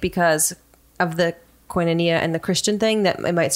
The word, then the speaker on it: Koinonia: Koinonia. – Koinonia.